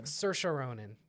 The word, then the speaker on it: Saoirse Ronan: Saoirse Ronan. – Saoirse Ronan.